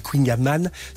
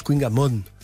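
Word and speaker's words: kouign-amann: Si on dit Queen Yaman, c'est Yann Kefelec, donc quand même il fait référence. – Référence, oui. – Qui prononce Queen Yaman.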